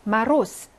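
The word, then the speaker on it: 마로스 (мороз): 두 번째, 마로스 극한의 추위를 말하는 건데요. 어말 무성음화 현상이 일어나서 맨 뒤에 오는 유성음 Z는 S로 발음이 나죠.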